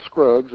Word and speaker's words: Scruggs: My name is Phil Scruggs. It's Phil as in Philip. My last name is spelled S-C-R-U-G-G-S.